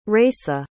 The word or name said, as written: Rayssa